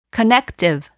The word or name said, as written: connective